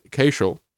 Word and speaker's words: Cashill: His name is Jack Cashel. And it rhymes, as he said, with Dashiell, if you remember that guy from politics.